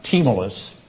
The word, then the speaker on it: Tmolus: The city of Sardis lay at the base of Mount Tmolus. That's how that word is pronounced, Tmolus, in the fertile valley of Hermas, in which a lower city and upper city were separated from one another by a 1,500-foot acropolis.